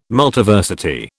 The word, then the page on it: Multiversity: British English